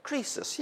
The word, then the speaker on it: Croesus: Croesus, yes, thank you.